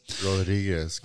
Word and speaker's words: Rodriguez: Rodriguez. – Oh, Come oh, on, man. I need that R in there.